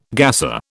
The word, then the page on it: gasser: British English